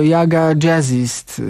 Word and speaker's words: Jaga Jazzist: Może wstyd powiedzieć, ale ja to z reguły przyznaję się do takich rzeczy, że jeszcze przed audycją do końca nie wiedziałem, jak, i powiedziałem to tydzień temu na antenie, wymawiać nazwę tej grupy. No ale podsłuchałem, że to Jaga Jazzist, dokładnie, tak się nazywa ośmioosobowy skład z Norwegii i ich ostatni bardzo mały, bo zawierający tylko cztery utwory krążek, The Tower.